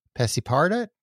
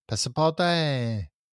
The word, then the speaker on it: Passepartout: Passepartout? Passepartout.